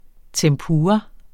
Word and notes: tempura: [ tεmˈpuːʁɑ ]

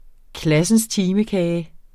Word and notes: klassens time-kage: ˈklasənsˈtiːməˌkæːjə

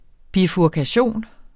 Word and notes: bifurkation: [ bifuɐ̯kaˈɕoˀn ]

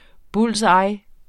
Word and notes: bull's eye: ˈbuls ˈɑj; ˈbols ˈɑjː

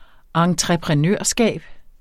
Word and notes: entreprenørskab: ɑŋtʁεpʁεˈnøˀɐ̯ˌsgæˀb; ɑŋtʁʌ-

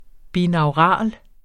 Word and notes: binaural: [ binɑwˈʁɑˀl ]; [ ˈbinɑwˌʁɑˀl ]

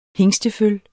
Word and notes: hingsteføl: [ ˈheŋsdəˌføl ]

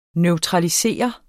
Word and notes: neutralisere: [ nœwtʁɑliˈseˀʌ ]; [ nεw- ]

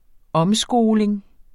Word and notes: omskoling: -ˌsgoːleŋ; -ˌsgoˀleŋ

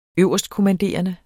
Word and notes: øverstkommanderende: -kɔmanˌdeˀʌnə; -komanˌdeˀʌnə

